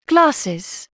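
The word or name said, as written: glasses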